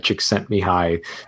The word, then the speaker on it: Csikszentmihalyi: Mihai Csikszentmihalyi.